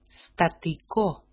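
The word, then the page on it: statiko: Greek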